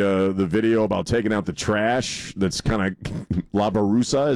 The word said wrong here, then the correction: basura: is it? Did I say that correctly? – Basura. – What's that? – Basura. – Basura. Okay.